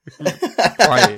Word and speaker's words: Pliny: Okay, no, no, he said Pliny. He said Pliny the Elder. And I said, oh, I said Pliny. And then he corrected me. Pliny. I was like, oh, all right. That's the right way to say it. So I bought it, whatever. And then I was talking to you on the phone, and I kept saying Pliny. Or Pliny. – No, Pliny.